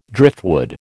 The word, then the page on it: driftwood: American English